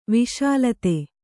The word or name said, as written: ವಿಷಲತೆ